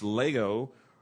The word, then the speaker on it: lego: It's almost like Legos, but it's Lego. It's L-E-G. It's a short E and long O, Lego.